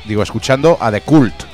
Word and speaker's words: The Cult: Llevo diciendo The Cult como un ceporro toda la puta vida. Y es The Cult.